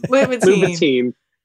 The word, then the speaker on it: Louboutin: What's like the Louboutin of, am I saying that right? What's the Louboutin of the time? – Louboutin. Yeah.